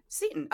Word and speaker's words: Seton: Is that the way to say that? Is it Seton? – Seton.